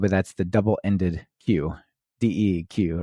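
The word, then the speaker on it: deque: And but yeah, the collections module has this thing called dequeue. Or deck, I think some people pronounce it.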